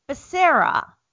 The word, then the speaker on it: Becerra: Oh, Becerra.